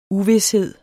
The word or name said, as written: uvished